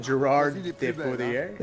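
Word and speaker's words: Gérard Depardieu: Gerard Depardieu? – Yeah, there we go. You're so much better pronouncing than I am.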